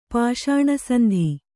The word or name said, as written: ಪಾಷಾಣಸಂಧಿ